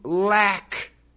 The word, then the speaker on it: lack: Now, today's expression is to lack something. L-A-C-K. Lack. Yeah, that ah sound is difficult. That American short A. Ah. Lack. Lack. To lack something.